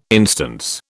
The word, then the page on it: instance: British English